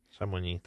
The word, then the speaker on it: Chamonix: I rok 1924 rozpoczęły się pierwsze zimowe igrzysko Olimpijskie we francuskim i jak to język francuski ma w zwyczaju, prawdopodobnie czyta się inaczej niż się pisze więc zaryzykuję, nie znając totalnie języka francuskiego, było to pewnie jakieś Chamonix. – Chamonix, tak.